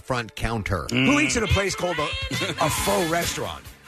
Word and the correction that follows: pho: Well, faux is a dish, P-H-O. – Oh, I'm sorry. – And in fact, it's not even pronounced that way. – Pho. – Is it pho? – Yeah.